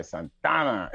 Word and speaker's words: Santa Ana: Yeah, you gotta say it right, Santana.